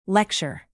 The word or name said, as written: lecture